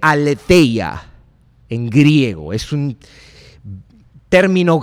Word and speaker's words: aletheia: La palabra verdad como un sustantivo se escribe aletheia, A L E T H E I A, aletheia.